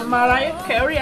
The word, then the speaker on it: Mariah Carey: E é uma versão da música internacional da Mariah Carey, é assim que pronuncia? – Uh -huh.